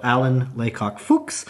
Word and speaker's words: Alan Laycock-Fuchs: I'd say the best way you can find it is, is by searching my name, so Alan Laycock Fuchs.